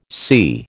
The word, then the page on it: sea: American English